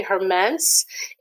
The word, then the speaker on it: Hermence: So just simply Hermence, H E R M E N C E.